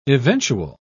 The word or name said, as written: eventual